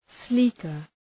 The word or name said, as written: sleeker